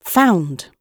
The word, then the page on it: found: British English